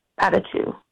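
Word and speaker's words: Patachou: Patatou. – Patatou.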